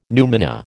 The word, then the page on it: numina: American English